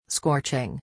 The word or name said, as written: scorching